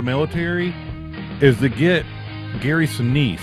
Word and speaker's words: Sinise: I think you need to pronounce his name correctly if you're going to have him on. – Gary Sinise. – It's Gary Sinise.